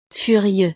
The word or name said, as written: furieux